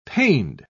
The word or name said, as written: paned